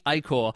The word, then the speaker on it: ichor: That's the peak ichor. But now it seems to become a thing again, ichor, and lots of ichor. Let me see if I'm saying it right. – Ichor. – Yes, got that right.